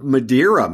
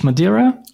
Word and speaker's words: Madeira: So that's how we pronounce it in English, Madeira. – Yes. – Okay. Yeah, we say Madeira, but probably so. It's Madeira. It's nice.